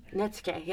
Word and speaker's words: netsuke: And it was sort of this representation of sort of the study of, I'll probably butcher the pronunciation of this, but Japanese Netsuke? – Netsuke, yeah. – Netsuke, great.